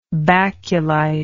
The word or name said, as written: baculite